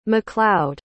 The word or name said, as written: mccloud